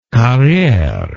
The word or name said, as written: carriere